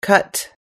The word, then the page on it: cut: American English